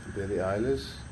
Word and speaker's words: Billie Eilish: Billie Eilish? – Billie Eilish.